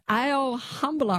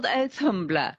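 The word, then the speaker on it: Auðumbla: It starts with a cow called Ale Humbler, I think. Forgive me, the pronunciation's not right. – Oathumbla. Yeah, she's called Oathumbla.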